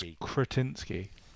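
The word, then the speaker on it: Křetínský: Vratinsky. Go on, you say his name. – I think it's Kretinsky.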